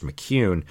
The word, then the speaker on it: McKeon: It's spelled McKeon, but it's pronounced McCune.